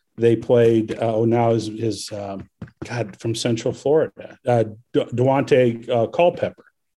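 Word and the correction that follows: Daunte Culpepper: Yeah, am I saying that right? – Dante Culpepper? – Yep, I think he played against Nebraska when he was in college.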